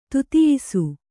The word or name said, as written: ತುತಿಯಿಸು